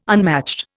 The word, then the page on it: unmatched: Indian